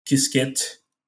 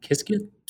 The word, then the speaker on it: Qiskit: Um, I would say um, IBM Qiskit is another one that I can refer anybody who wants to. – How do you that. spell that? – Qiskit.